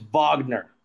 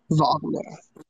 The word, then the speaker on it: Wagner: But I'm glad that – I want to correct myself real quick. I always pronounce their – stinking names wrong. I did it with his brother too. It's Wagner. There we go. – Wagner.